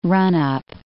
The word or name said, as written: run-up